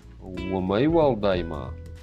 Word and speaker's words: Умайвалдайма: И ударение э, на В вот эту вот первую. Умайвалдайма.